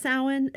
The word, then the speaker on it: Samhain: S-A-M-H-A-I-N.